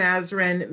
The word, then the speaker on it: Azrin: I do want to say too that she said that she used the Foxx and Azrin method and that it absolutely worked.